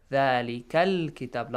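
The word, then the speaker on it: ذلك الكتاب: You cannot skip lam. You skip Hamzatul Wasl, but you don't have to skip that lam. ذَلِكَ الْكِتَاب. Lam is pronounced. Why? Because kaf is a moon letter.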